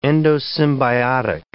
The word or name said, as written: endosymbiotic